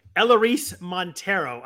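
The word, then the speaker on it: Elehuris Montero: Ellerice Montero. I looked I looked up the pronunciation. That's how the AAA announcers for his prior team say.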